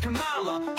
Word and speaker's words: Kamala: It's pronounced Kamala, hope and no fear.